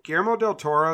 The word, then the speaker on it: Guillermo del Toro: If Guillermo del Toro, is that how you say it? – Yeah.